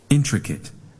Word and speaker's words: intricate: Intricate. – Your turn. 3, 2, 1. – Intricate. – One more time. – Intricate. – นักเรียนปลายทางอย่าลืมออกเสียงตามไปด้วยนะครับ So everybody, we put the stress on which syllable? The first, second, or third syllable? Where is the stress? First syllable. So we pronounce it intricate.